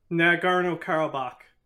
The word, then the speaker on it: Nagorno-Karabakh: Nagorno-Karabakh.